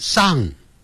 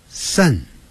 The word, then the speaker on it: sun: 太阳叫 sun，哎，不要念成上，不是念上啊。你看，有点像一二三的三，sun。